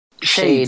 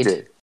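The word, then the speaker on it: Sade: Eu não sei pronunciar direito também. A Shade, sabe? não sei como é que fala. – Shade. Shade. – É Shade mesmo? Yes. Uhum.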